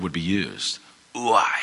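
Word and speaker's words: ouai (οὐαί): It's pronounced ouai. It's what you call an onomatopoeia. So an onomatopoeia is a word like buzz. It sounds like what it is.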